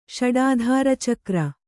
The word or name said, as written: ಷಡಾಧಾರಚಕ್ರ